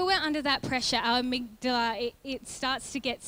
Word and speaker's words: amygdala: what happens is there's a response in our amygdala. Any medical people here? Can, I'm saying it right? Amygdala? Amygdala? Yes, good.